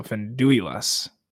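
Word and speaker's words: Finduilas: I was like, as I was reading, it was like Fendulas, but I guess there is that I there. So Fendulas. – That's how Christopher Lee pronounces it.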